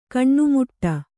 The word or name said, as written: ಕೊನೆಮಟ್ಟಿ